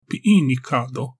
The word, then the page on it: Pinika-do: Galindri